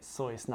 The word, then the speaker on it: Seuss: See, Dr. Seuss, and that's how you say it, Seuss, not Seuss, I know.